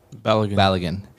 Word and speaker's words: Balogun: where they're getting new players like Bulligan, who's, like, had a great season. Is how you pronounce his name? – Balogun.